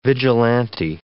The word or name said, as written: vigilante